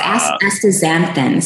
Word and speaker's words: astaxanthin: And then also astaxanthin. I think I'm saying it right. – Yeah, so astaxanthin.